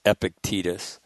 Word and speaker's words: Epictetus: But Epictetus, I had to listen to the pronunciation of his, and it's almost two words, Epictetus,